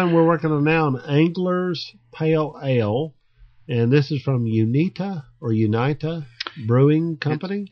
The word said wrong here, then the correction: Uinta: It's actually UINTA. Unta. – Uinta. – Uinta. – Nah, hell, I don't know how you say it. – Uinta. – It was easier when I thought it was Unita.